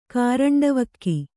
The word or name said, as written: ಕಾರಂಡವಕ್ಕಿ